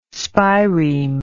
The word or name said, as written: spireme